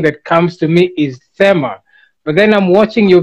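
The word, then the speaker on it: Thema: Yes, the A is silent. So it's Tema, like Tema Lion.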